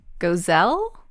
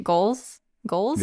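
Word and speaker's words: Goelz: We have, I'm probably going to get this name wrong, and Sam can correct me, but Dave Gozell? – I don't know that name. – Goals? – Yeah.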